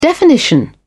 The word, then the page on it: definition: British English